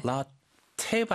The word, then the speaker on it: latte: 영어로는 T가 두개 있어도 따가 아니고 네. – 라테 발음을 많이 하더라고요.